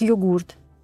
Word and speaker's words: йогурт: В этом плане очень интересная история с прекрасным словом йогурт годами в словаре оно было вписано как йогурт. И это было правильное ударение.